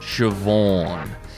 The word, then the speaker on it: Siobhan: It's pronounced Siobhan.